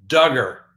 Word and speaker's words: Duggar: So first on the docket, Josh Duggar. That's right. Rhymes with hugger.